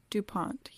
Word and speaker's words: DuPont: I believe it's pronounced DuPont, yeah.